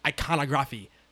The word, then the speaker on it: iconography: and American iconog- iconography that the right word? Iconography. – Iconography. Thank you. – right word, wrong pronunciation. wrong emphasis on the wrong no, syllables.